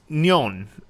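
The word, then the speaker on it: nyon (년): But is pronounced nyon rather than nian.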